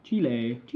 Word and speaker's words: Chile: Because I know I'd like to go to Argentina or Chile, maybe. Chile? Why did I say it like that? – Chile. I do not know.